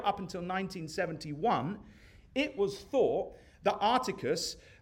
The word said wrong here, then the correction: Arcturus: Arcturus, sorry, someone help? Arcturus, thank you, brother. Arcturus, glad we got that corrected.